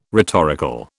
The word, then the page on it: rhetorical: British English